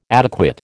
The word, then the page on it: adequate: American English